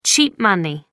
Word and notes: cheap money: American English

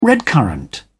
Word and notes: redcurrant: British English

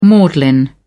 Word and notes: maudlin: British English

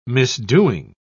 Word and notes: misdoing: British English

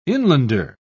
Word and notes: inlander: British English

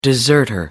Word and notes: deserter: American English

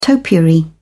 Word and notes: topiary: British English

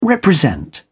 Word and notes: represent: British English

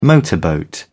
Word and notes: motorboat: British English

